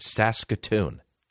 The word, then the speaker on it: Saskatoon: Yes, it's called, yes, that one fire is typing out, is that how you say that? Yeah, that's how you say it, Saskatoon.